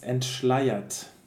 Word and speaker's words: entschleiert: Um, it's entschleiert. So um, the EI combination and then ER afterwards, it's really hard to know how to, how to uh, spell that but, um, or how to pronounce it.